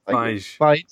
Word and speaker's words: Feige: Feige.